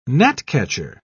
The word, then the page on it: gnatcatcher: British English